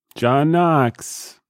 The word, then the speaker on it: Knox: John Knox. – K. – With a silent K.